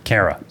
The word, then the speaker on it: Cara: Is it Cara or Cara? – Cara. – So yeah. Cara. Sorry.